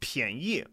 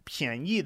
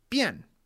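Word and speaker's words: Pianyi (便宜): So, of course, Pianyi, the first character is Pian, which is normally pronounced Bian, B-I-A-N, fourth tone. Now it's P-I-A-N, second tone, Pian versus Bian, right?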